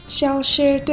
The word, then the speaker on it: 消失: 啊，对对，消失对吗？ – 嗯，这回对了，你刚才发音发成小事了。